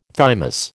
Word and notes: thymus: American English

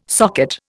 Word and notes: socket: Indian

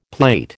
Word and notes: plait: American English

